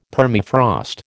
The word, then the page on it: Permafrost: American English